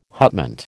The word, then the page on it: hutment: American English